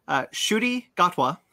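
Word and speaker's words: Ncuti Gatwa: It's pronounced Shuti Gatwa, mm-hmm.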